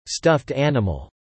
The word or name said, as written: stuffed animal